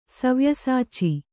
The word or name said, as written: ಸವ್ಯಸಾಚಿ